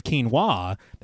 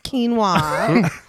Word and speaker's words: quinoa: Quinn assures him that she did go shopping, and he reaches into the fridge, pulling out a container of quinoa that he mispronounces as quinoa. It's, of course. Yeah. – Oh my God, Dad. It's quinoa.